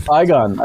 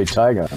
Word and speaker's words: Tigon: Tigon. I'd probably say Tigon. – Yeah. – Like tiger.